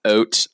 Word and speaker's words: Haute: So it's funny because like it is spelled HAUTE, which anyone who speaks even a modicum of French will tell you that is oat, like oat dog.